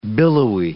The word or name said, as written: billowy